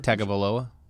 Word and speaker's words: Tagovailoa: Tagovailoa? – Yeah, you got it right.